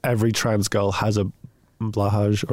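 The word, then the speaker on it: Blåhaj: I mean, every trans person, really, it's considered a rite of passage for the most part to own a blahaj. Or blow high, depending on how you want to pronounce that one. – It is pronounced blow high, but I think it's controversial because it's trans girls who have blow high.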